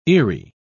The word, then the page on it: eery: British English